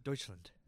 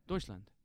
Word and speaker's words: Deutschland: How do you say it? – Deutschland. – Deutschland. – You said Deutschland. – English accent, you know? – Yeah.